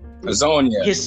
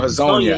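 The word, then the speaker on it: Hezonja: And even, I wouldn't even say Mario, I don't even know how to pronounce it, Hassana? – Hazonia. Mario Hazonia.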